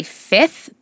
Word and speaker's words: Fivth: I was influenced and I bought a rollerball perfume called Hollywood Hills by Fifth, but it's spelled F-I-V-T-H. – I don't like these. That's like that pure, but it's P-H-L-U-R.